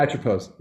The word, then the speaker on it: Atropos: Is it Atropos? Is that how you say it? – Yeah, Atropos. Yeah.